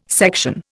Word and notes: section: Indian

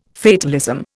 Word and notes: fatalism: Indian

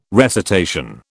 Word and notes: recitation: British English

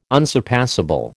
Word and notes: unsurpassable: American English

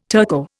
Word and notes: Turco: Indian

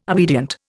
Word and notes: obedient: Indian

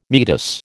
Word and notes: meatus: American English

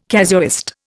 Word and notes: casuist: Indian

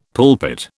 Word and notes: pulpit: British English

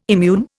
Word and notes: immune: Indian

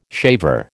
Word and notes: shaver: American English

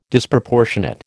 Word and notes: Disproportionate: American English

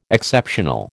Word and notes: Exceptional: American English

